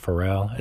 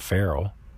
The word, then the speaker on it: Pharrell: Isn't that how you say it, Pharrell? It's not feral, like a feral cat. It's Pharrell, the guy that sings the happy song.